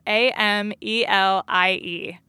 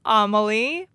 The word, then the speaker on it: Amelie: It's A M E L I E. A lot of people would pronounce it Amelie, but in my family, it's pronounced Amelie.